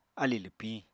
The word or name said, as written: alì lwpiñ